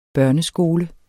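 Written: børneskole

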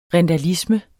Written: rindalisme

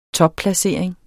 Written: topplacering

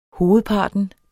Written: hovedparten

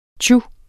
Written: tju

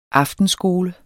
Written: aftenskole

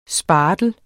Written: spartel

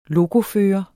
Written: lokofører

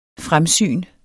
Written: fremsyn

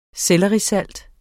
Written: SELLERISALT

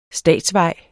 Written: statsvej

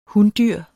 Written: hundyr

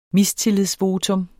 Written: mistillidsvotum

